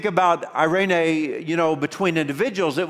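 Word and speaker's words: Irene (εἰρήνη): And the word that we have for peace in ancient Greek, I gave you the pronunciation, it's pronounced Irene.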